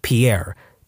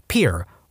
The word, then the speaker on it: Pierre: She kept saying Pierre, two syllables, like the French name. And he said it was Pierre, one syllable, like what you fish off of.